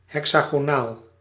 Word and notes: hexagonaal: /ˌɦɛk.saː.ɣoːˈnaːl/

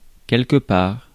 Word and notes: quelque part: France; /kɛl.kə.paʁ/

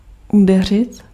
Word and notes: treffen: /ˈtrɛfə(n)/

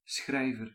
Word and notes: schrijver: /ˈsxrɛi̯vər/

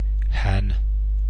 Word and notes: han: /han/; /hɒːn/

